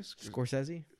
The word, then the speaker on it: Scorsese: And it's one thing, how do you say his name? – Scorsese.